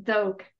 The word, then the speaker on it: Doak: Doak. – Doak. – Like okie dokie? – Okie dokie.